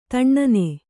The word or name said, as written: ಧೂನನ